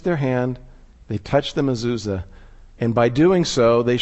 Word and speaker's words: mezuzah: It's called a mezuzah. Did I pronounce it right? More or less. And inside the mezuzah is the Shema.